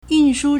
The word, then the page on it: 运输: yùnshū